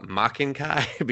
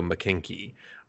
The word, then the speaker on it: Maken-ki: The way it's spelled, though, I always call it Makenkai because it's M-A-K-E-N. And there's no like capital K. But when you hear them say it, they always go McKinky.